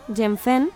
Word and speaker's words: Yuanfen: Proviene del chino, Yenfen, y se escribe con Y y U-A-N-F-E-N, e Yuanfen.